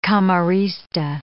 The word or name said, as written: camorrista